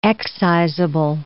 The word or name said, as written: excisable